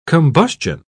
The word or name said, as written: combustion